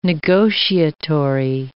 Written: negotiatory

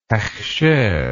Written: hechsher

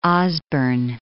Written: Osborn

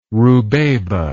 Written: rubeba

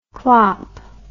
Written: klop

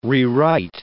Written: rewrite

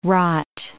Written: rot

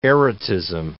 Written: erotism

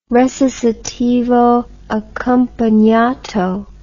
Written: recitativo accompagnato

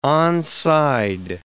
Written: onside

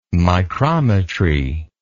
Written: micrometry

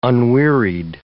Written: unwearied

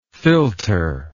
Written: philtre

